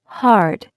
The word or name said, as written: heart